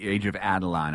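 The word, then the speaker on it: Age of Adaline: Movie number three, Age of Adeline.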